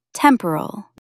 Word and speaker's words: temporal: Stress the first syllable. Tem-p-ral. Try saying it aloud. Temporal.